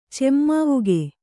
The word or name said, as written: ಚೆಮ್ಮಾವುಗೆ